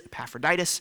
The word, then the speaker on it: Epaphroditus: I just am really proud that I can pronounce it. So there it is, Epaphroditus.